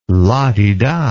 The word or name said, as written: la-di-da